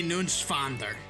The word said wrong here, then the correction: Neuenschwander: It's N-E-U-E-N. It's Neuenschwander. Anthony Neuenschwander.